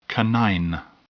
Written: canine

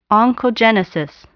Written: oncogenesis

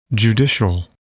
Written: judicial

